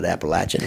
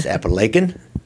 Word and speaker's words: Apalachin: If you remember last week, we learned how to pronounce Apalachin. It's not Appalachian, it's Apalachin.